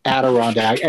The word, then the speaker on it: Adirondack: O-N-D-A-C-K. – Yeah. – Adirondack. I thought it was Adirondack. – Yeah. Adirondack. And it's, you know, of course, the famous chairs, the Adirondack chairs.